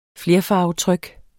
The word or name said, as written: flerfarvetryk